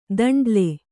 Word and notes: ದಂಡ್ಲೆ: Kannada

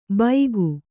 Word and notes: ಬೈಗು: Kannada

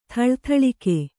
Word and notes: ಥಳಥಳಿಕೆ: thaḷa thaḷike

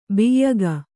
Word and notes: ಬ್ಯಾಗು: byāgu

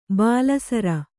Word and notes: ಬಾಲಸರ: bāla sara

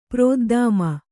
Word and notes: ಪ್ರೋದ್ದಾಮ: prōddāma

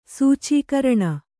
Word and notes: ಸೂಚೀಕರಣ: kannada

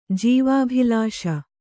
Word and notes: ಜೀವಾಭಿಲಾಷ: kannada

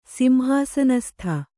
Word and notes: ಸಿಂಹಾಸನಸ್ಥ: simhāsanastha

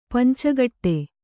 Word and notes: ಪಂಚಗೌಡ: panca gauḍa

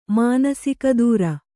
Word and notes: ಮಾನಸಿಕದೂರ: mānasika dūra

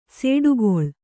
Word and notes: ಸಿಡಿಗೊಳ್ಳು: siḍigoḷḷu

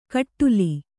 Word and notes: ಕಟ್ಟುಲಿ: kannada

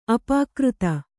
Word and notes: ಅಪಕೃತ: apakřta

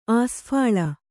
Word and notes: ಆಸ್ಫಾಳ: Kannada

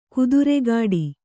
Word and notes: ಖುದ್ರಗೇಡಿ: Kannada

